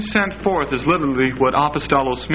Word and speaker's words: apostolos: Now, the word apostle actually is a transliteration from the Greek word apostolos. Apostolos. And that's spelled A-P-O-S-T-O-L-O-S.